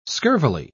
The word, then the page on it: scurvily: British English